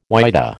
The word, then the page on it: whydah: American English